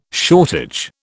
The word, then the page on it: shortage: British English